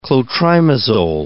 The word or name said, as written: clotrimazole